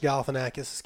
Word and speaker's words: Galifianakis: And Will Ferrell's going to punch Galifianakis' character, and he moves out of the way, and I'm proud of myself for saying that correctly.